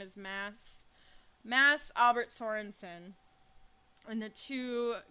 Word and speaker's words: Mads: It's spelled M-A-D-S, but it's pronounced Mass in Norwegian. – Hmm. – The D is silent.